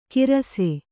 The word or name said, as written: ಕಿರಸೆ